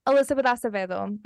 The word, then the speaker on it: Elizabeth Acevedo: Elizabeth Acevedo.